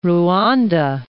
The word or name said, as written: Rwanda